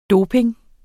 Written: doping

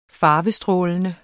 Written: farvestrålende

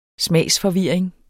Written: smager

